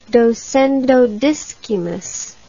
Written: docendo discimus

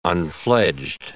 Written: unfledged

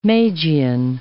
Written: Magian

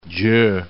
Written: Gyor

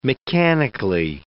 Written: mechanically

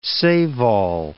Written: save-all

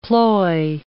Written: ploy

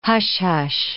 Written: hush-hush

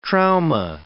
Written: trauma

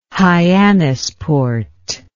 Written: hyannis port

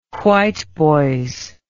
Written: whiteboys

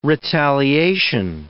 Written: retaliation

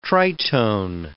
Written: tritone

